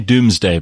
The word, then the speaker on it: Domesday: They say Doomsday, but Doomsday, you spell it Domesday Book, yeah.